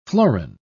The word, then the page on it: florin: British English